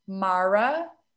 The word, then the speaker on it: Mara: My name is spelled J-O-S-I, Mara, M-A-R-A, Richards with S at the end.